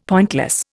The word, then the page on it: pointless: Indian